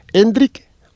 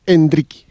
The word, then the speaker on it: Endrick: enrique Ah, me ha dicho que la pronunciación es Endrick o Endrique. Rodrigo, te he dicho Hendrik.